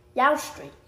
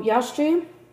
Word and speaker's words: Eostre: Yaustri. Which is spelled E-O-S-T-R-E, which is spelled super similarly to Easter, and there's a reason for that. So, Yaustri, pre-Christ, we get the name Easter for this holiday from the...